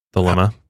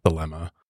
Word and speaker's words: Thelema: So Thelema, it's been pronounced a few different ways. I think going forward, we'll just pronounce it Thelema. – Thelema.